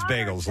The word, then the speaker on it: bagel: It is bagels. Long A.